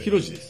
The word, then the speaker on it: 浩次: あれ浩次って読むんですかそうですよ浩次じゃないんですね広次です